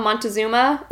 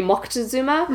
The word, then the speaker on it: Moctezuma: And he is linked to the idea that the last Aztec emperor, I looked up the pronunciation of his name because we call him Montezuma, or he could be Moctezuma, mm-hmm.